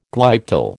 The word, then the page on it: glyptal: American English